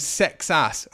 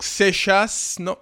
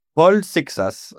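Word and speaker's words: Seixas: But that is indeed how he prefers to us to pronounce his surname, Sexas, not Xixas, not anything else.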